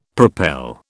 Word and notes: propel: British English

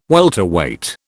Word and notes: welterweight: British English

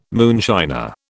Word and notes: moonshiner: British English

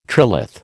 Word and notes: trilith: American English

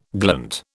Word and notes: glint: British English

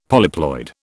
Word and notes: polyploid: British English

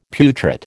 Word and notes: putrid: American English

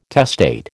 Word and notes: testate: American English

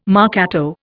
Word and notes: marcato: Indian

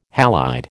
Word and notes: halide: American English

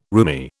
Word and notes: roomie: British English

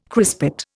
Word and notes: crispate: Indian

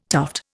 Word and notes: tuft: Indian

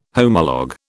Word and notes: homolog: British English

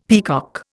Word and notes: peacock: Indian

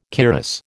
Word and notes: cuirass: American English

